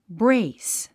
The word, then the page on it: brace: bréis; ブレイス